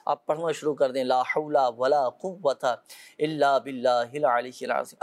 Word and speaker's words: لا حول ولا قوۃ: اچھا یہ بھی ہوتا ہے, صحیح تلفظ تو یہی ہے نا, لا حول. اچھا بعض اوقات لوگ کیا لا حول ولا قوت, اب یہ اس انداز سے پڑھیں گے تو پھر تو وہ فوائد حاصل نہیں ہو سکیں گے نا, تو صحیح تلفظ کے ساتھ ہی اس کو پڑھا جائے.